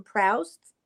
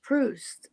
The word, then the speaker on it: Proust: I always called him Proust. And then Amory Nieves says, it's Proust.